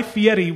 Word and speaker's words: Fieri: It's Guy Fieri. We check all our pronouncers.